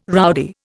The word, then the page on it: rowdy: Indian